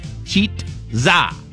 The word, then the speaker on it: Chizza: KFC is rolling out a new menu item called the Chizza. Uh, the name is a mashup of chicken and pizza, if you didn't figure that out. It's pronounced cheat za, like it rhymes with pizza.